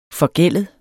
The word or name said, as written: forgældet